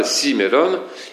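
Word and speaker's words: simeron (σήμερον): Or ce mot aujourd'hui, qui est simeron en grec, eh bien, il est accentué sur la première syllabe, simeron, mais ce simeron, donc pour l'accentuer encore plus, engendre des mélodies appropriées dans le chant byzantin.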